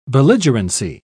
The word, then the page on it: belligerency: British English